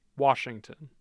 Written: Washington

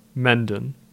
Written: Mendin

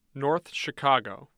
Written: north chicago

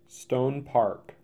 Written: Stone Park

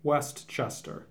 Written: Westchester

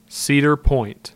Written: Cedar Point

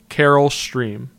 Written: Carol Stream

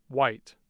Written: White

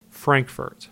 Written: Frankfort

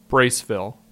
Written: Braceville

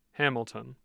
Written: hamilton

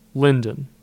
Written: Linden

